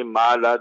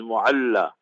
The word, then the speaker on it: Mu'alla: They pronounce it wrong also. They say Ma'ala. There's no word like Ma'ala. It's Mu'alla.